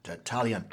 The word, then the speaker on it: Tertullian: But, Tertullian, and I hope I'm pronouncing that correctly. – That's cool. How I say it, so okay. We'll go with it. – Tertullian, excellent.